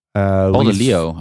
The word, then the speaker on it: Paul de Leeuw: Paul de Leeuw.